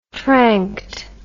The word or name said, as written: tranked